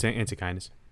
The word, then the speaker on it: Antechinus: That's what we settled on. Or Antechinus. – No, it's Antechinus. – Antechinus, and then the species is Stuartii. – Stuartii. – Yeah, Antechinus Stuartii.